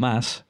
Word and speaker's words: Mads: So Mads, or Mass, is a form of Matthew, meaning gift of God.